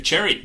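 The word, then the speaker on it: Xerri: Tristan Zeri. I think that's how you pronounce it. – Cherry. There we go. Cherry. Tristan Cherry.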